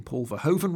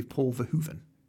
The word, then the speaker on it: Paul Verhoeven: on point with our pronunciations is we have been contacted by a Dutch listener who's told us that we're pronouncing Paul Verhoeven wrong, and it should be Paul Verhoeven. So apologies to Paul Verhoeven. – Our Dutch listeners, it's Paul Verhoeven.